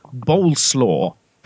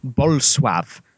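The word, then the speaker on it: Bolesław: His middle name is what I thought was Boleslaw. But apparently, if you do the proper Polish pronunciation, it's Bolsław.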